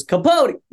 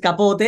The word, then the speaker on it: Capote: It depends. You know, if I want to remind myself of my high school teachers, it's Capote. But it's Capote in Spanish, but in English it's Capote.